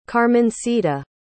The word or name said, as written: carmencita